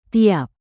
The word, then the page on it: tiap: Indonesian